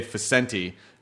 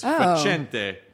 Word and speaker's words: Facente: But my family did the American pronunciation because their last name they say Facenti. But it's Facente.